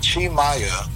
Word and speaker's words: Tim Maia: And Chi Maya, which how we spell it is Tim, T-I-M. Mm-hmm. But Chi Maya, which is T-I-M-M-A-I-A,